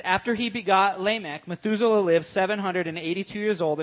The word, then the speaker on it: Lamech: Noah's father was Lamech. Is that how you pronounce that? Lamech? – Sure.